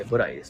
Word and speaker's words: BURAI: その中でも私が一押ししたいのはブライえー、これはですね、アルファベットで BURAI と書いてブライですね、うん、えー、住宅街に突如現れる隠れ家で、おしゃれ静かな、えー、焼き鳥店です。